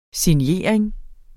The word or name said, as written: signering